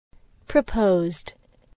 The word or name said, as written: proposed